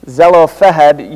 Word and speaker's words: Zelophehad: Here in chapter 36, the tribe of Manasseh expresses concern that the daughters of Zelophehad, no, I'm not saying that right, Zelophehad,